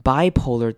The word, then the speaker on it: bipolar: consider Consider, consider that depression and bipolar disorder. Bipolar 这个字要注意的是它的重音在第一个音节 bipolar, bipolar，不要把音节放到后面。